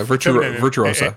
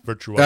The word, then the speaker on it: Virtuosa: Virtuosa. – Yeah. I said it right the first time? – Yeah Virtuosa. – A is feminine. Virtuosa. – That's right. Virtuosa.